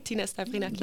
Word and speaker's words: Stavrinaki: Tina Stavrinaki.